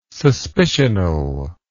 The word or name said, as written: suspicional